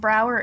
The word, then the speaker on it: Brouwer: And Brouwer is Dutch, so it's spelled differently than you might think. It is BROUWER.